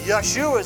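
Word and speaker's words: Yeshua (ישוע): Yeshua is how you say it in Hebrew.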